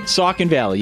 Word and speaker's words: Saucon Valley: Saucon Valley? – Saucon Valley, you got it.